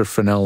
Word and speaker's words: Fresnel: Those are Fresnel lenses. It's spelled Fresnel. The S is silent.